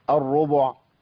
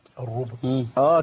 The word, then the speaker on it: الربع: زي ايه الربع. – الربع. – كتير ناس بيقول الربع بيسكن الباء يسكن الحرف في النص عشان ما ايه بيصعب عليه ثقل الضم.